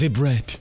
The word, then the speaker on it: Vibert: His name is Vibert. – Vibert? – V-I-B-E-R-T. How would you pronounce it? – Vibert. – Vibert? – Vibert. – Okay. – It's Vibret.